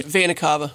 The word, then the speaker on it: Vena Cava: Am I pronouncing that right? – Vena Cava.